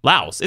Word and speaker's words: Laos: Laos, I think. Laos. The S is silent.